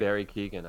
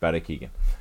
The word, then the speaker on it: Barry Keoghan: Barry Keoghan, I think. – Barry Keoghan.